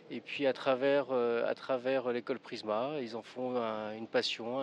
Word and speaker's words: Prizma: Alors moi je dis Prisma et en fait c'est bien Prisma parce que ça s'écrit P-R-I-Z-M-A.